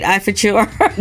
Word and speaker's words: Ifeachor: Ifeachor? – You did.